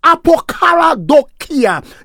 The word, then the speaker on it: apokaradokia: That expression, translated earnest expectation, beloved friend, comes from a Greek word, apokaradokia. And it's okay, you do not have to look to pronounce it. It's apokaradokia.